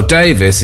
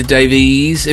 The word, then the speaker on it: Davies: Well, Davis is the name. Some people like to lean into Davies. In fact, I was only the other day, I was corrected by someone on how to pronounce my name. I said it's Alan Davis, D-A-V-I-E-S, and he said, oh, Davies. I said, no, you don't need to lean heavily. I mean, if you do, it didn't matter, but it's wrong.